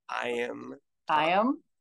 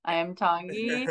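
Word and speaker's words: Iam Tongi: And then I know I was not watching American Idol this season, but how do you say his name? Is it I am Tongi. – I am Tongi.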